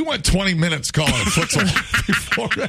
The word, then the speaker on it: futsal: Is it futsal? – Futsal.